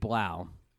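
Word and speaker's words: Blough: By the way, what's interesting, Drew Locke and David Blau, you have to think of it. It rhymes with when the bow breaks.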